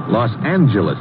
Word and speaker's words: Los Angeles: Later, we moved out to California, Los Angeles. Correction. Los Angeles. Oh? Yeah, soft G.